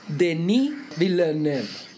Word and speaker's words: Denis Villeneuve: Y lo primero que tenemos que saber es que la pronunciación o mi aproximación a la pronunciación de nuestro tema de hoy es Denis Villeneuve. Es Denis en vez de Denis y es Villeneuve en vez de Villeneuve.